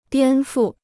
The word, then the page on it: 颠覆: diān fù